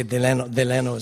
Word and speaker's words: Delano: What he used to say, Delano is the way he pronounced it. Because we all know what ano means, right? – Let's move on. – Anus. Of the anus, if you say Delano. So he would say Delano.